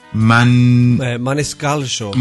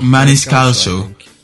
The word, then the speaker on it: Maniscalco: Maniscalco. – Maniscalco.